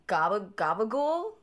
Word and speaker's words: gabagool: Oh, my friend sent me an article about gabagool, which is apparently this. – It's a kind of cheese, but it's like a super Sicilian pronunciation of it. – It's a super Sicilian pronunciation of capicola.